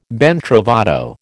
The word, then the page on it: ben trovato: American English